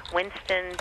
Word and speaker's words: Winston: So it's Sherry, S-H-E-R-I, Winston, W-I-N-S-T-O-N.